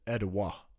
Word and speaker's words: Atiwa: So some people will say Etowa. In the book, it says Etowa is the way that you say it. – How do you spell it, just so people know? – A-T-I-W-A. – There you go. – The reason I'm saying that is, like, I don't want you to hear that and then, like, go look up E-D something.